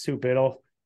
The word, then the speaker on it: Sue Biddle: This week, my guest is Sue Biddle.